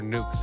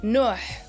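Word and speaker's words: Nuux: Is it Nux or Nukes? How's it pronounced? – It's actually the X is silent, so it's Nuh, but you could just say Amaal.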